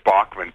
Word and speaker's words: Bachman: which they then put out a thing that said Bachman, B-O-C-K, like as a pronunciation.